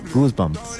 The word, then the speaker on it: Goosebumps: Es de, se llama, bueno, ayúdame a pronunciarlo. – Goosebumps. – Ajá, eso.